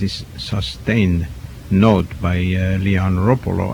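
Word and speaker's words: Roppolo: The people who know Italian tell us that it should be pronounced with the accent on the first syllable, so it's Ropolo. Ordinarily or commonly people pronounce it, I believe, Ropolo, but that's not correct.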